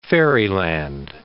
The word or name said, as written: fairyland